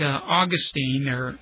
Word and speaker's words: Augustine: In fact, Augustine, if you're really spiritual, that's how you say it.